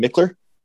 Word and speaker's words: Michler: But we are joined today by Ryan Michler. Tell me if I said that wrong, but I think I said it right.